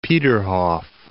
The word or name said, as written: Peterhof